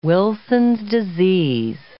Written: Wilson's disease

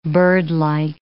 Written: birdlike

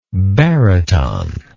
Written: baryton

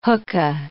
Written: hookah

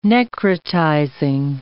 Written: necrotizing

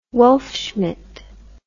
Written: wolfschmidt

